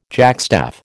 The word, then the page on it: jackstaff: American English